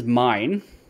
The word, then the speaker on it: Myne: Because her name is Mine, right? Spelt M-Y-N-E, but pronounced M-I-N-E. And sure. The show spells it M-A-I-N.